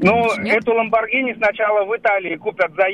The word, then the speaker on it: Ламборгини: Ну, во-первых, правильно говорить «Ламборгини», а во-вторых, хотел...